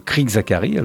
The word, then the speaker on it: Crick Zachary: Vous pouvez aller voir, n'hésitez pas à aller sur Google ou sur YouTube et faites Crick Zachary. Alors C-R-I-C-K, Crick Zachary, comme ça se prononce.